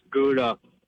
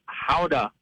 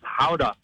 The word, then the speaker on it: Gouda: It's not pronounced Gouda. It's Howda. – Well, that sucks. That's not very fun. Howda? – Right? – I don't think I believe you, caller. – Yeah, it's Howda.